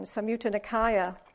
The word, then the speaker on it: Samyutta Nikaya: the Nikaya, Shumyata Nikaya. Samyutta. Samyutta, I didn't write it down. Samyutta Nikaya.